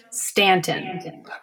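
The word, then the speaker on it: Staunton: It's pronounced Stanton.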